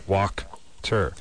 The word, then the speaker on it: Wachter: It's phonetically, it's W-O-K. – T-E-R, so Walkter.